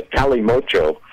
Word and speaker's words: Kalimotxo: And it's called a Cali Mocho, or Mocho for short. Now that's spelled K-A-L-I. M-O-T-X-O, Cali Mocho.